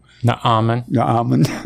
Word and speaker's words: Naaman: Okay, now, point I bring out here is that Naaman, I assume that's how you pronounce it. – Naaman.